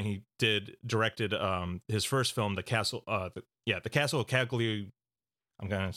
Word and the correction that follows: Cagliostro: Cagliostro. Yeah. The Castle of Cagliostro.